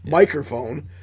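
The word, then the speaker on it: Mikerphone: But we're going to start it off with Just Like Honey, a New England hazy IPA from Microphone, and no, I'm not mispronouncing that. It's spelled M-I-K-E-R, phone, microphone, brewing.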